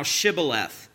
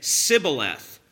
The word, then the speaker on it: Shibboleth: If he said, 'Nay,' then said they unto him, 'Say now Shibboleth.' And he said, 'Sibboleth,' for he could not frame to pronounce it right."